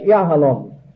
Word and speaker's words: yahalom: I should have mentioned the diamond Hebrew word, that's yahalom, the accent more than the last syllable, representing or meaning hardness.